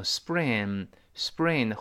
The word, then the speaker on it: spring: 我们知道在 s 后面呃重读的时候呢，这个清辅音一般要浊化。你像 spring。不对，要读成 spring，spring 后面轻轻的是 b b，不是 p。